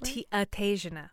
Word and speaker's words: Ataegina: Did you want to say anything else about, is it Antesia? Is that Athasiana. – Athasiana. Gosh, that's beautiful. – It's spelled A-T-A-E-G-I-N-A in the Portuguese.